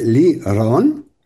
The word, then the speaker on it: Lirón: que realmente es Lirón, con esa R un poquito más pronunciada, porque se refiere a que yo tengo un cántico.